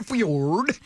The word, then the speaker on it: fjord: Out there, out the fjord. – That's the correct pronunciation.